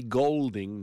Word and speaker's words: Goulding: It's interesting because she does spell it G-O-U-L-D-I-N-G, but she says, call me Golding, not Goulding.